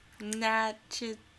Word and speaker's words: Natchitoches: Natchitoches. – I don't know how else you'd say that. Natchitoches, yeah.